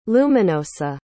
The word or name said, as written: Luminosa